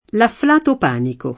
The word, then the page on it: panico: p # niko